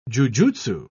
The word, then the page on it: jujutsu: British English